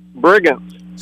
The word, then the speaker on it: Brigance: Brigant. – Spell it. – B-R-I-G-A-N-C-E.